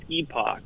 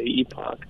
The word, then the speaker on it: Epoch: If you're in the UK or Canada, it's Epoch. And some of our hosts are Canadian, and so they say Epoch. But if you're American, the technical pronunciation is Epic.